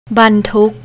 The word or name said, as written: bantook